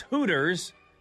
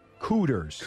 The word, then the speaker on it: Hooters: Everybody knows when tried to pronounce Hooters, he instead said... – Cooters.